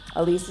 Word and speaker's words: Ilise: Elise is spelled I-L-I-S-E.